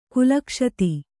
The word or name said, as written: ಕುಲಕ್ಷತಿ